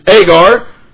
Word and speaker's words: Agar: which generous to bondage," which is Agar, New Testament spelling, pronunciation for Hagar, his mother, the Egyptian.